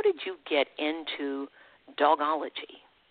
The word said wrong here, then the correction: Dogtology: Am I saying it right? – So dogtology. – Dogtology, all right.